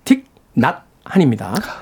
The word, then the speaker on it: 틱낫한: 예, 전에 이거 틱낫한이라고 자꾸 발음을 잘못했는데 틱 낫한입니다.